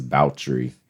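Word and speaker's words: Báthory: We're saying that her name is Bouchery because that's the pronunciation.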